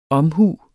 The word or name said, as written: omhu